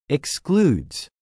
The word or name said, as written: excludes